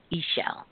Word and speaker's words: Ix Chel: And so she is connected to the cosmic energy web Ix Chel, and it's spelled I X, second word C H E L, Ix Chel.